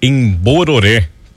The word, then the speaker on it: Embororé: E quero, quero, entrando no Bororé, pronúncia correta ali, foi abreviada, né? Seria o Embororé, que era uma localidade jesuíta.